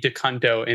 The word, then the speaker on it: DeCunto: And I did the pronunciation for it because I'm like, there's no way. There's no way it can be DeCunto. And it absolutely is.